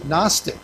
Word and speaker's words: Gnostic: I think you drop off the first letter. It starts with a G and then it goes to an N. You know, I would kind of pronounce it Gnostic. But if you look at it, it's got this G, N, O.